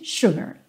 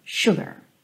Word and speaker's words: sugar: Mas isso é uma situação dessa canção, dessa melodia, normalmente sugar, sugar.